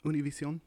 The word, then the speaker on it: Univision: Yeah, Univision.